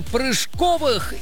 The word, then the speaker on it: прыжковых: Завтра в столичном парке Музеон впервые пройдет фестиваль прыжковых или прыжковых видов спорта. Выбирайте, где здесь поставить правильное ударение. – Прыжковых. – Да. Да, прыжковых! Это именно так!